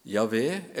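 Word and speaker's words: Jahve: Det står fire konsonanter. J, H, V, H og det må uttales Javé eller Jehova.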